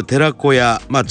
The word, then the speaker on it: 寺子屋: 今日は NPO 法人フリースクール寺子屋代表理事の加藤千尋さんと電話がつながっています。加藤さん、おはようございます。 – おはようございます。加藤です。 – よろしくお願いします。さて、フリースクール寺子屋、地球の小屋とか言って寺子屋と読むわけですけれども、 1996 年に保護者の皆さんが中心となって作られまして、現在は熊本市中央区の国部で活動していらっしゃるということですね。